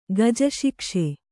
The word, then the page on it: ಗಜಶಿಕ್ಷೆ: kannada